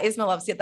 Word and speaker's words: Ismailovsky: So if you were in Croatia, how would they pronounce it? – I think they would say Ismailovsky. – Ismailovsky. – Like Ismailovsky. Yeah.